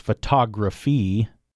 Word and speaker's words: photography: Okay, so up next, similar to photographer, I guess, is photography. Photography, yeah, okay. So that has this, like photographer, it has the stress on that second syllable, the antepenultimate syllable.